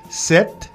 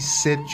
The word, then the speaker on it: sept: N-E-U-F. Neuf. Un, deux, trois, quatre, cinq, six, sept, huit, neuf. Je dis un, trois, six, sept. C'est pas sept. C'est écrit S-E-P-T. S-E-P-T. Pero la P no se pronuncia. Uh-huh. Entonces tenemos que decir... – Sept. – Sept. Como en inglés, S-E-T.